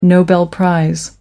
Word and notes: nobel prize: American English

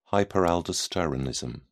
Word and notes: hyperaldosteronism: British English

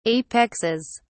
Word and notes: apexes: British English

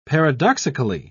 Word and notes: paradoxically: British English

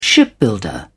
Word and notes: shipbuilder: British English

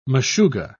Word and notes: meshugga: British English

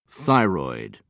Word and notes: thyroid gland: British English

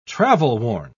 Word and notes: travel-worn: British English

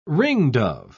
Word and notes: ringdove: British English

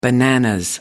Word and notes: bananas: American English

